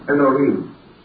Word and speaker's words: Elohim: Part of it is written from left to right and consists of A-L-H-I-M. We generally pronounce that as Elohim, or more perhaps correctly, Elohim.